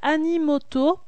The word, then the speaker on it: Animoto: Rendez-vous ensuite aussi sur un site que j'aime beaucoup qui s'appelle Animoto. .com. Alors il s'écrit Annie, A-N-I, et moto comme le véhicule, mais ça n'a rien à voir, .com.